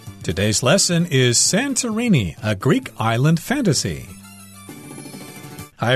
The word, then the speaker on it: Santorini: You might pronounce this as Santorini. But uh, I'm going to say Santorini because that's the way Americans pronounce it if they see the word and it's a Greek island fantasy.